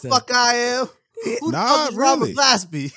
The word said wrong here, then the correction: Glasper: If you said it right the first time, it would have been really funny. – I didn't say it right the first time? – You said Glasby.